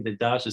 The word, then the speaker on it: Dedaj: My last name, Dedaj, is spelled D as in David, E as in Eddie, D as in David, A as in Apple, J as in James.